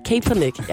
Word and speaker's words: Kaepernick: Kaepernick. – Kaepernick, ja.